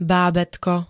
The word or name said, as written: bábätko